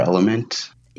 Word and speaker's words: LMNT: Because every time I look, it's salt and I did try that LMNT stuff, or element. – Yeah, I always want to call it LMNT also, because that's how it's spelled out, the element electrolytes.